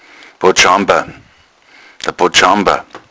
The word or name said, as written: botschamber